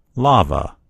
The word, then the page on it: lava: American English